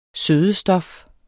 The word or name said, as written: sødestof